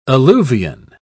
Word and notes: alluvion: British English

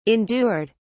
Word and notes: endured: British English

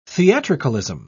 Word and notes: theatricalism: British English

